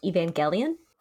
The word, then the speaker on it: Evangelion: First things first, it's pronounced Evangelion.